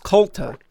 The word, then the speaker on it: Kulta: What I can pronounce is the band's name, which is Kulta. – With a K. – Kulta. – Yes. – K-U-L-T-A.